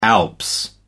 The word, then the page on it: the alps: American English